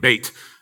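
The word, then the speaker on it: Beit (בית): In Israel, when you say Beth, we say Beth, it's pronounced like Beit. Beit Sefer.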